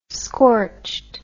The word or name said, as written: scorched